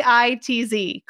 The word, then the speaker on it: Peitz: Chelsea and then Peits for Chelsea Pites. – No, wait. That's not how you spell it. – Did I say it wrong? I swore I read it on the screen. – Peitz. Close.